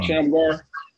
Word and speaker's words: Shamgar: Shamgar.